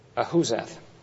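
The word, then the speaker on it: Ahuzzath: Well, what do you suppose Ahuzah means? I don't think I'm pronouncing it correctly. Ahuzath.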